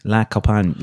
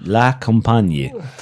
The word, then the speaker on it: La Compagnie: How would you say that? La Compagnie? La Compagnie. La Compagnie.